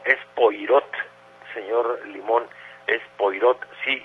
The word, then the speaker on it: Poirot: se escribe Poirot, es una eh, apellido francés y se, se pronuncia Poirot, o en un francés así muy depurado sería Poirot.